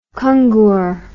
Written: kungur

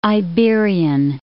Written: Iberian Peninsula

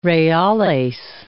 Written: reales

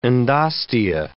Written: endostea

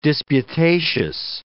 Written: disputatious

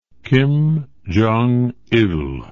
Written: kim jong il

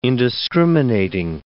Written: indiscriminating

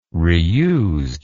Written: reused

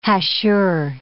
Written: hachure

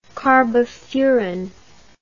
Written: carbofuran

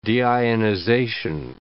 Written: deionization

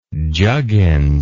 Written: juggins

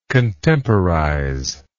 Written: contemporize

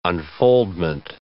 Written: unfoldment

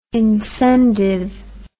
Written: incendive

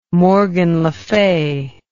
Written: morgan le fay

